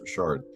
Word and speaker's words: Richard: And he had trouble his whole life with people thinking that his name was actually Richard Blakeney because my last name is spelled like Richard, but it's French, you know, pronounced Richard.